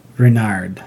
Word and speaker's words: Renard: Renard.